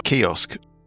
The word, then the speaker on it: Kyuss: How should I be pronouncing it? – Kios. – There's no rule. Kios, I pronounce it. – Kios. – It's K-Y-U-S-S. – Yeah, Kios. – I was in chaos. – Well, it sounds a bit like chaos.